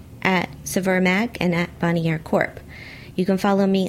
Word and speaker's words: Bonnier: No, it's Bonnier. – Okay. Bonnier.